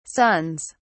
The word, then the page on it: sons: British English